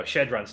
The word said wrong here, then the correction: Chadron: State. Indiana travels to Nebraska. – If you can't say it right, you don't get it. – Sorry, Chadron State.